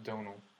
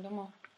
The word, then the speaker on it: Domhnall: I'll let you in on something. It's pronounced Donal.